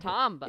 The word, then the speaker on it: Tombaugh: Tombaugh. – It's T-O-M-B-A-U-G-H. How would you say Bo? Tombo? – I think Tombo. – B-A-U-G-H. Tombaugh.